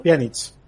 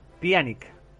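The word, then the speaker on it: Pjanić: Eh, no me sale la pronunciación. Muchas gracias, Imantadas. Por el sub. – Pianic. – Pianic. Pianic.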